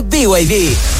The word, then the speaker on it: BYD: Nada que ver, se escribe BYD, pero se dice BYD, la marca número uno de electromovilidad en Chile y el mundo.